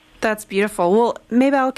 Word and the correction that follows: Mabel: am I pronouncing your name right? – Mabel. – Mabel. Mabel.